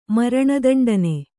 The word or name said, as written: ಮರಣದಂಡನೆ